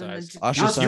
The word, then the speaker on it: ostracized: It's the whole argument of like, well, you know, I'm going to be singled out and I can be, you know, ostracized or whatever the fuck it's called. – Ostracized. You were close. You were missing one syllable.